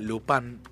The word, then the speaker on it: Lupin: Lupin, en realidad, Lupin, como lo pronuncian en Francia.